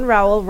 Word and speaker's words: Rowehl: John Rowehl rhymes with towel.